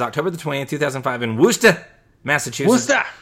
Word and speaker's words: Worcester: Worcester. Yeah, Worcester.